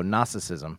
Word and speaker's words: Gnosticism: And then there arose this thing called Gnosticism, mm-hmm, spelled with a G. So if you're looking it up and Googling it, it's GN. So Gnosticism.